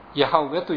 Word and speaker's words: ヤハウェ: ですからその代わりにアドナイっていう主という言葉の母音をつけて呼んでいたわけですけれども、その聖なる皆を呼んでいたわけですけれども、ですから学者はヤハウェという発音だったんじゃないかというところで、今日の学者たちがその辺で同意していますけれども、一昔前はエホバでした。